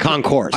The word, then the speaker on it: Concours: It's spelled with an S at the end, but it's Concours, correct? Well, or is it Concours? – I believe it's pronounced Concours d'Elegance. Yeah, d'Elegance. Silent.